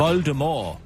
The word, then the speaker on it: Voldemort: Rowling, at det er en rigtig måde at udtale den unævnlige fyrstes navn på, er med et stumt T, altså øh, Voldemort.